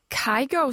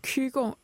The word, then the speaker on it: Kygo: Nu har jeg et spørgsmål til vores to gæster, fordi det her, det var Comrade SeaWorld, og så Kygo, som jeg kalder ham, men han hedder vel Kygo, eller hvordan? – Eller måske på norsk, Kygo.